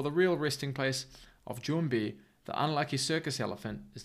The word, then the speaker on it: Jwmbi: That's Jumbi. Jessica's yet to record her part for this, and I haven't heard how she's going to say it, but I'm saying Jumbi. It's spelt J-W-M-B-I, so you pronounce that.